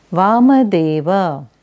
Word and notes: Vamadeva: Sanskrit

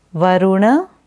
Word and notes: Varuna: Sanskrit

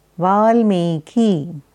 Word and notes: Valmiki: Sanskrit

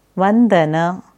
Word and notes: Vandana: Sanskrit

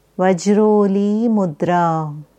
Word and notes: Vajroli Mudra: Sanskrit

